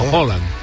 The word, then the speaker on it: Haaland: que a todo esto es muy curioso cómo se pronuncia, nosotros lo pronunciamos como Lafa. – Es como Haaland.